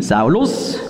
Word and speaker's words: Saulus: Und griechisch ausgesprochen hat eben Shaul eben Saulus und Sheil Silas.